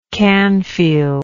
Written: canfield

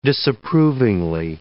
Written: disapprovingly

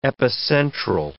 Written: epicentral

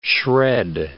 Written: shred

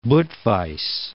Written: Budweis

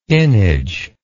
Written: innage

